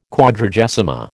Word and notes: Quadragesima: American English

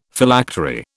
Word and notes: Phylactery: British English

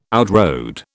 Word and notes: outrode: British English

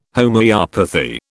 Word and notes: Homoeopathy: British English

